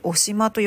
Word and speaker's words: 渡島: えー、では檜アスナロの分布についてなんですけど檜アスナロは北海道渡島半島を北限とし、ね、渡る島渡島と書いて渡島と読むんですね。